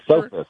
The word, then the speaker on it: Esopus: And I think I've correctly pronounced Esopus Creek. Is that Esopus? – Yes.